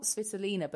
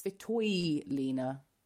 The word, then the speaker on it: Svitolina: But apparently it's not Svitolina, but it's Svitoylina. So a Ukrainian mm. twist to that name, Svitoylina.